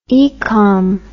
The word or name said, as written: ecom